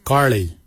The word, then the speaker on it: giilyi: Dab is spelled D-H-A-A-B. It is a traditional knife, a dab. For boomerang, we say garli. Garli is the traditional name for boomerang. Garli is spelled G-I-I-L-Y-I, garli. For the traditional stone axe, we say goj, goj. Goj is spelled G-O-O-D-J, goj.